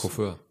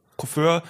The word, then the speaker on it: Coiffeur: Wie, wie spricht man das aus? Coiffeur. Coiffeur ja.